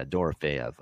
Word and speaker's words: Dorofeev: Is it Dorofeev? I always say Dorofeev. Maybe that is the part I should know. Feyev. – Okay, there you go. Yeah, Dorofeev.